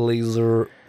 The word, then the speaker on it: laser: Man uttalar det som ett Z, men det stavas med S. La laser, laser.